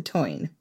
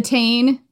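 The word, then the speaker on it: Táin: We did an episode about the cattle raid of Cooley, and throughout it, we called it the Tane, which is not the correct pronunciation as far as I can tell.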